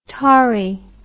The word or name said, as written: Tauri